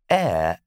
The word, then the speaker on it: heir: Air and air are pronounced exactly the same.